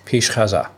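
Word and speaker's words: Pish Gaza (پیش غذا): P-I-S-H. – P. Oh. Okay. P-I-S-H-G-H-A-Z-A, right? – Right. – Pish Gaza.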